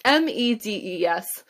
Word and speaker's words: Medes: They were settled in colonies in Hala, along the banks of the Habor River in Gozan, and in the cities of the Medes or Medes, I don't know how to pronounce that properly. It's Medes.